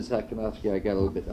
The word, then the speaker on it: Sakhnoffsky: Sakonofsky, S-A-K-H-N-O-F-F-S-K-Y, Sakonofsky.